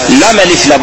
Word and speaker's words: lam elif: Okursanız la la şeklinde okunuyor. Okurken la, ismini söylerken la melif.